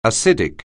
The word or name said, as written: acidic